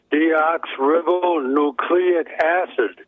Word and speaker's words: deoxyribonucleic: It's pronounced deoxyribonucleic acid.